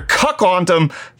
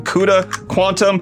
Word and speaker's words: CUDA Quantum: Let's talk about NVIDIA and their cuckwandom. What? Cuckwandom framework. I know that's not how I'm supposed to pronounce it, but it's, it's supposed to be like CUDA quantum, NVIDIA's quantum, which is, which, NVIDIA, Nice.